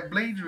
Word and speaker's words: Blade: Então, puxando aqui o início da pauta, é, para quem não conhece, Kamen Rider Blade, é Blade mesmo, tá? Igual o herói da Marvel que se escreve, Blade.